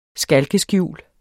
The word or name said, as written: skalkeskjul